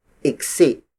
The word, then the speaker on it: iksi: Igsi, which is spelled as I-G-S-I. And iksi. Iksi. Iksi. Which is spelled as I-K-S-I.